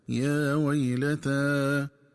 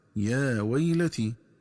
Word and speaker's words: يا ويلتا: وكذلك ينبغي التنبه للنطق الصحيح للفظ يا ويلتا، فيقرأ بفتح التاء وبعدها ألف مقصورة هكذا يا ويلتا، ومن الخطأ قراءته بكسر التاء وياء ساكنة بعدها هكذا يا ويلتي.